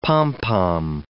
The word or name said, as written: pom-pom